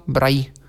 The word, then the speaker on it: Braille: BR chyba apostrof EEE, tak się to pisze, Braille, no bo po francusku, a firma pochodzi z Francji, tak wymawia się nazwisko Ludwika Braille'a, nie jest to Braille, tylko jest to Braille.